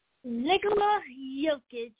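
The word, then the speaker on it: Jokić: And if I can get his name right, is that Nikolai Jovic? – Nikola Jokic. – Jokic. I was close.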